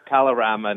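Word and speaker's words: Kalorama: So the is it pronounced Calamara? Neighborhood? – Calorama neighborhood. – Calorama, thank you.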